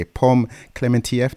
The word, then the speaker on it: Clementieff: I just have to say, Pom Clementieff, that's how you say her name, as Paris, the French assassin, she's brilliant.